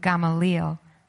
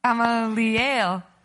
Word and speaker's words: Gamaliel: But a Pharisee in the council named Gamaliel, I said it wrong. Gamaliel,